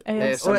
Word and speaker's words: ASMR: And as I probably will pronounce wrong again, our AMSR, is it? – ASMR. – ASMR, sorry. AMSR. I pronounce that wrong every time.